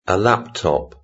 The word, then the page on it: a laptop: British English